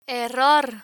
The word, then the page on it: error: Spanish